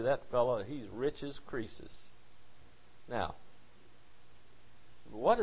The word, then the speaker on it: Croesus: C-R-O-E-S-U-S. You might read it Croesus, but I think it's pronounced Croesus.